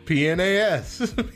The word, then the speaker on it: PNAS: PNAS.